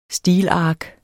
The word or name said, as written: stilark